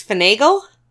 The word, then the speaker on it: finagle: I say finagle wrong. It's finagle. – Oh, I go both ways. – Of course you do. – With finagling and finagling. – But I've heard it's supposed to rhyme with bagel.